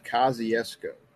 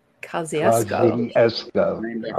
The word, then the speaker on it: Kosciuszko: Well, first off, the town that we were actually in is pronounced Kosciuszko. – Kosciuszko.